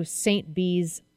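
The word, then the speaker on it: St Bees: So I swam from Keswick to St. Bee's Island. Am I saying that right? – You are.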